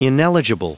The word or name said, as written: ineligible